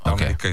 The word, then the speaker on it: Dominica: So that's the official way to pronounce it. Dominica.